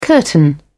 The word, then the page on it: curtain: British English